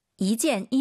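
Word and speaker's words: 一件: 이젠, 이후. – 이젠. 숫자 1이 2성으로 바뀌어야 돼요. 뒤에가 4성이니까 숫자 1은 2성입니다. 이젠 이렇게 가야 됩니다.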